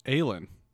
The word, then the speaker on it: Aislynn: A-I-S-L-Y-N-N. – Aylin. – Aylin.